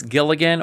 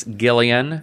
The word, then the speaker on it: Gilligan: We've got writer-director Vince Gilligan, or as I said in the last podcast, Vince Gillian.